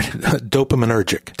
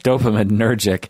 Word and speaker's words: dopaminergic: Dopaminergic. – Dopaminergic pathways.